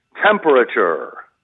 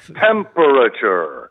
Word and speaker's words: temperature: What I really would like to see change with people who forecast or tell you about the weather is the pronunciation of temperature. – Oh, is you hear how many syllables I've given it? Temperature. It's not temperature.